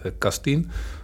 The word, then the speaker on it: Kastien: De naam spreek je ook goed uit, Kastien. Dat is Nederlands, Erik Kastien om volledig te zijn.